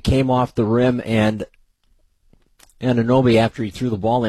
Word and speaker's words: Anunoby: The ball was thrown in by OG, but I never know how to say his last name, Anunoby. OG Anunoby.